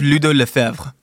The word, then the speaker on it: Ludo Lefebvre: Chef Ludo Lefebvre.